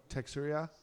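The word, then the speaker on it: Teixeira: Are you talking about Mr. Jack Tarek Texera? I can't say his name right. – Yes. – Texera? – I believe so, yes.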